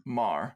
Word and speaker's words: Maher: How do you pronounce her last name? – Maher. Like Bill Maher.